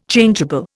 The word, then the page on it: Changeable: Indian